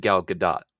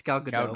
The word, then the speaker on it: Gal Gadot: We just went through a thing about pronouncing names, and you asked about Jeff Johns, but you said Gal Gadot. That's We're going to have some issues here. – What do you say? – It's Gal Gadot.